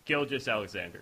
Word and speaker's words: Gilgeous-Alexander: Gilgeous-Alexander.